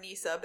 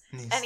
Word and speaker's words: NESA: It's either pronounced NESA or NISA, but it's NESA.